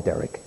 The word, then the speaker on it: Derick: Cut it in half and call him Derek." So I'm the other half of Frederick. That's why I spell it D-E-R-I-C-K.